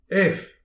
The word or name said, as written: ev